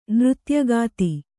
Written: ನೃತ್ಯಗಾತಿ

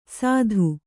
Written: ಸತ್ತು

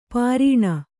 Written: ಪೈರಾಣ

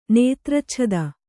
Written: ನೇತ್ರಚ್ಛದ

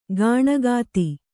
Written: ಕಣ್ಗೆತ್ತು

